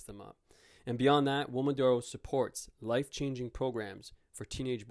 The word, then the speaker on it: Uomodoro: It's called Uomodoro. It's spelled U-O-M-O-D-O-R.